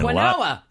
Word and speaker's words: quinoa: Q-U-I-N-O-A. – And Quinoa. – A lot of people say mm-hmm. Quinoa. – Mm-hmm. – Other people say quinoa. – Quinoa? – Quinoa. – Quinoa. – That's what they say, but the correct pronunciation is? – Quinoa.